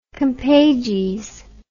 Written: compages

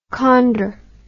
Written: chondr

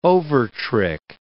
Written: overtrick

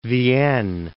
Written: Vienne

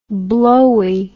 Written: blowie

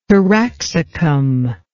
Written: taraxacum

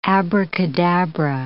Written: abracadabra

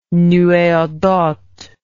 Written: nuee ardente

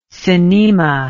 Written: synnema

